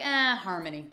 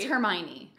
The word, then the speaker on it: Hermione: I think it's Hermione. – It's Hermione. – Hermione. – Tomato, tomato. – I never saw or read any of the Harry Potters, and no one writes the emails. It's never gonna happen. – I read them, but I'm a lazy read, so if I can't pronounce a word, I make up the pronunciation. – It's definitely not Harmony.